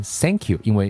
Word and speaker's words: thank you: 比如说中文中很多中国朋友为什么读不好 th 这个音？Thank you，我们会读不好，不读成 Thank you，我们读成 Thank you，因为在中文中是没有 th 这个咬舌的发音的，所以我们自然而然就会读成 Thank you，Thank you，Thanks。